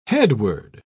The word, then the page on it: headword: British English